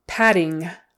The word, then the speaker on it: padding: Two D's, it's just a D sound in the middle. Padding. Again, that G on the end of the ing is not usually pronounced. In certain areas of the country, it might be, but typically don't pronounce the G on the ing.